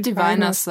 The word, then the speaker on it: Diviners: Ich würde Diviners sagen.